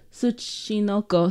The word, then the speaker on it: Tsuchinoko: Shonioko. – All right. Shonioko, I will be calling... – Oh, no. Tsuchinoko.